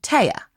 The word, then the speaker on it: Tea: Her name is, in fact, Thea. No H. – Right, spelled T-E-A, but not pronounced T, obviously, pronounced Taya.